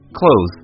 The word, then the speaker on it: clothes: Right, clothes. It sounds like the opposite of open.